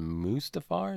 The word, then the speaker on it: Mustafar: And for those counting at home, the first duel was in Revenge of the Sith on Mustafar.